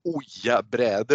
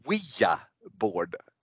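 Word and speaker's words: Ouija board: Och vi får ju också lära oss att det heter inte Oja-bräde utan Wia board Eh, det, hur har du uttalat Wia board tidigare, Kristoffer?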